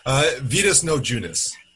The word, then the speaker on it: Vitas Naudziunas: Vitas Naudziunas.